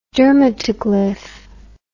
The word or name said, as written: dermatoglyph